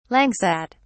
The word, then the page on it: langsat: British English